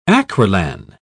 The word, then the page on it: acrilan: British English